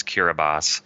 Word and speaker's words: Kiribati: So when you see T-I, that is S. So the country that looks like Kiribati is Kiribati. The capital area of Tarawa is spelled B-E-T-I-O, but it's pronounced Beso.